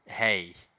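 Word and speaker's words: hej: It's like a H, I think it's a H-E-J, and it's pronounced hey.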